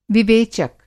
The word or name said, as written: विवेचक